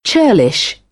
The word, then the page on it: churlish: British English; ɜː